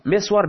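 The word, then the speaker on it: مِسْوَر: و هو مسور به کسر المیم و سکون السین المهمله و فتح الواو المخففه، مانند مسور با این توضیحی که گفتیم با کسر داشتن میم مس و سکون سین مس فتح داشتن واو مسور.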